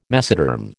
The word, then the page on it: mesoderm: American English